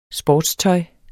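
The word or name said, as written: sportstøj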